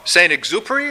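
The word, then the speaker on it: Saint-Exupéry: Serving mission rather than members reminds me of a quote from Saint Exupery.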